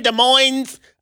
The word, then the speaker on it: Des Moines: They chilling somewhere in Des Moines, Iowa. I know you don't pronounce the S, but they're in Des Moines, Iowa.